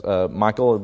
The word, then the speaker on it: Michal: It's Michal, basically, is how it's pronounced.